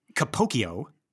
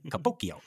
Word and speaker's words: Capocchio: Capocchio, that is his name. Like, and I've heard people say it out loud. Capocchio, I really mm-hmm. like that, right? Because it is spelled like Pinocchio, right? Yes, it's the C-C-H-I-O, right? Capocchio.